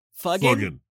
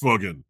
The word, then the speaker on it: fuggin': Fuggin'. That's pronounced fuggin', not fudge. – F-U-G-G-I-N. I fuggin' love it.